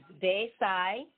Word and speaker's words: desayuno: And if you're ready with your pen and paper, it's spelled D-E-S-A-Y. U-N-O, desayuno, or like D-say-uno.